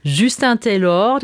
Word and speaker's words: Justin Taylor: Es kommt von Justin Taylor, der spricht sich tatsächlich, auch wenn er sich Englisch schreibt, Französisch aus.